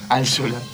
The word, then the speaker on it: Altschuler: Yo llamándome Klimesuk no me animaría a pronunciar el apellido, Jerónimo. ¿Cómo es? – Altshuler. – Altshuler con 24 millones de consonantes. – Es muy complicado. – 5 consonantes seguidas.